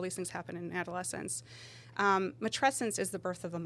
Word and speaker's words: matrescence: So, I love this term matrescence. So it rhymes with adolescence, and you think about it's this huge transition in a person's life.